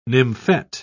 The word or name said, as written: nymphet